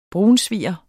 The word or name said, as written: brunsviger